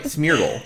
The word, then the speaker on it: Smeargle: Smeargle? – Smeagle? – Smeargle? – There, Smeargle. Yay. – Smeargle. Okay. I'll call it Smear because it smears. I don't know. – No, it's right. Smeargle.